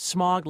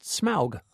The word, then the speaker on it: Smaug: I always thought it was pronounced smog, like the Los Angeles pollution, but it's not. It's pronounced Smaug.